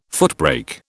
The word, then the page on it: footbrake: British English